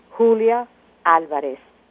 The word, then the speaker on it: Julia Alvarez: Of course, since I'm Dominican-American, half and half, it's always nice to have it both ways, my short American name pronounced the Spanish way, Julia Alvarez.